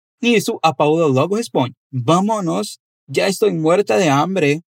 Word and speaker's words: vámonos: Note que há um acento em ba, então a pronúncia mais forte se encontra ali. Me chame para ir com você na padaria. Vámonos.